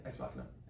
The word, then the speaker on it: Ex Machina: That's how you say it, Ex Machina.